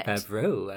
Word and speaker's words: Favreau: In Favreau, I like that. – Am I saying it wrong? – No, that's more French than how he said it.